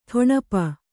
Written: ಟೊಣಪ

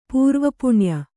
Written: ಪೂರ್ವಪುಣ್ಯ

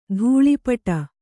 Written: ಧೂಳೀಪಟ

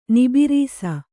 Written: ನಿಬಿರೀಸ

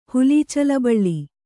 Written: ಹುಲೀಚಲಬಳ್ಳಿ

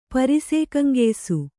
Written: ಪರಿಸೇಕಂಗೆಯ್ಸು

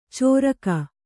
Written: ಚೋರಕ

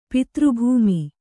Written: ಪಿತೃಭೂಮಿ

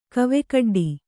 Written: ಕವೆಕಡ್ಡಿ

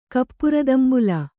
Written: ಕಪ್ಪುರದಂಬುಲ

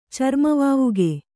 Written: ಚರ್ಮವಾವುಗೆ

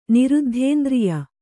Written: ನಿರುದ್ಧೇಂದ್ರಿಯೆ

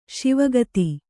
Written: ಶಿವಗತಿ

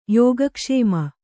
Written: ಯೋಗಕ್ಷೇಮ

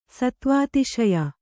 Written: ಸತ್ವಾತಿಶಯ

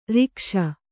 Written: ರಾಗಿಸು

